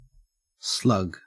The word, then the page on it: slug: Australian English